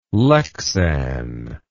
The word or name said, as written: lexan